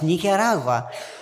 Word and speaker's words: Nicaragua: Certainly, to a Spanish-speaking person, Nicaragua is pronounced Nicaragua.